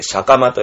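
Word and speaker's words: 斜構: 斜めに構えると書いて、シャカマと読みます。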